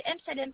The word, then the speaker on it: Emsalem: Ah, mon nom, c'est M Salem.